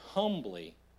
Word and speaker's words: humbly: Rather, serve one another humbly in love. Or we'll go old school how we heard it in church. Humbly. Like that silent H, wherever that is.